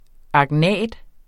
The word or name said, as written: agnat